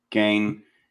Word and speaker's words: Gein: Gein. – Ed Gein.